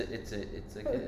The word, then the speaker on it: GIF: It's a soft J. It's a GIF.